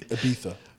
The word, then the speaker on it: Ibiza: It's well, Ibiza. Oh yeah, that, yeah, that's Ibiza. – And if we're pronouncing it natively, Ibiza.